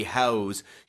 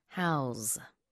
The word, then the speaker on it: house: 집 얘기할 때는 S 발음이 더 강해집니다. House. 근데 동사할 때는 house. 스펠링은 똑같아요.